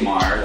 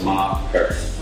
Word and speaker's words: Maher: They'll say Maher, like Bill Maher, oh. – Or Maher, blah. So I'll tell them to cut the syllables, Maher.